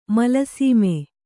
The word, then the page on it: ಮೇಲ್ಸೀಮೆ: Kannada